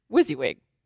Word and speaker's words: WYSIWYG: And you just pronounce it WYSIWYG. What you see is what you get.